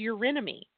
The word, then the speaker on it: Eurynome: Now, in the Greek mythology, in archaic Greek mythology, the water goddess or Neptune precursor was a goddess named Eurynome. I don't even know if I'm saying that right. E-U-R-Y-N as in Nancy, O-M as Mary, E. – You absolutely are.